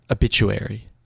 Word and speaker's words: obituary: How do you say it? – Habitual. – No. It's obituary.